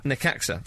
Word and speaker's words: Necaxa: And the first night game was on June the 5th between Valencia FC and, let me get the pronunciation right, Necaxa. Necaxa, thank you.